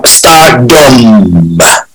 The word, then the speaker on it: Stardumb: Stardumb. As in D-U-M-B. The B is silent.